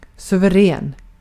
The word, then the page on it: splendid: /ˈsplɛndɪd/